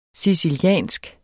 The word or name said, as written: siciliansk